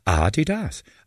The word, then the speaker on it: Adidas: Adidas, as they say in German, its original pronunciation is Adidas.